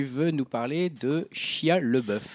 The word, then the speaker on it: LaBeouf: Alors, je ne sais jamais si on dit LaBeouf, LeBeouf. – Je crois que c'est LaBeouf. – Là, ça s'écrit LaBeouf. Moi, j'ai toujours dit Shia LaBeouf.